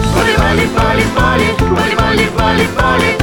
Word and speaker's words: Бали: А вообще-то правильно говорить не Бали, а Бали.